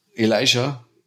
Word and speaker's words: Elijah: Anscheinend spricht man das jetzt anders Elijah aus. Elijah Wood. – Elijah, ja.